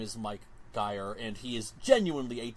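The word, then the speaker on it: Geier: I think he pronounces it Geyer. It's G-E-I-E-R. I'm assuming that's pronounced Geyer.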